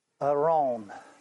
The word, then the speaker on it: aron: The word ark, I looked this up when I was studying it, is Aron. I'm not sure if I pronounced it right. It's A-R-O-N. Aron.